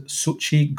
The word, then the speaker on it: Xochitl: has cast Mary Elizabeth Winstead and Xochitl Gomez.